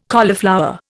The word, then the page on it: cauliflower: Indian